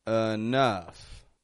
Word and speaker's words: enough: Notice I'm not saying enough or enough, making two strong syllables, but enough. I'm making the E in the beginning into a schwa sound, an sound. And then the OU in the middle are getting an sound as well, but a stronger one, a short U.